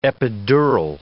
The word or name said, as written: epidural